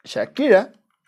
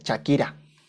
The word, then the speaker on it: Shakira: Y que incluso Shakira, no se dice Shakira, ay cabrón, mi cuello. Eh, no se dice Shakira, se dice Shakira.